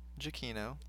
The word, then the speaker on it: Giacchino: And I said his name wrong because I didn't know how to say his name, but I know how to say his name now. – Go for it. – His name is Michael Giacchino.